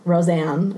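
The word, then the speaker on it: Rozan: Roseanne is how you pronounce her name, I learned.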